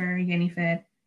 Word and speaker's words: Jennifer: but my first name is pretty easy in both languages. So it's like, you know, Jennifer, Yenifed, Jenny, Yenny.